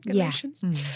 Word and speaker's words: Galatians: Galatians? – Yeah.